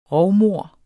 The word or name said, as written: rovmord